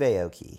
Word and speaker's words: Aoki: Oh, Steve Aoki.